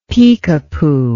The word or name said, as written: pekepoo